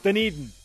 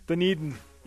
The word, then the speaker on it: Dunedin: Dunedin. – Dunedin? – Dunedin.